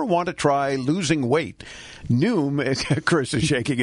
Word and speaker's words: Noom: Her new favorite app is Noom. That's how you say it? Like yes. – Noon with an M.